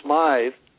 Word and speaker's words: Smythe: So if someone is named John Smith, S-M-I-T-H, and another one is named John Smythe, which they pronounce Smith, which is, S-M-Y-T-H-E, it's a different vibration.